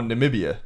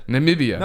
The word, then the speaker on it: Namibia: Oh, Namibia. – Namibia.